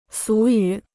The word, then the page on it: 俗语: sú yǔ